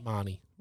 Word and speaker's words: Mahoney: Well, I was about to say to you, Reed, is it Mahoney or Marnie? – Marnie. – Really? – It's spelled Mahoney. Right. It's pronounced Marnie.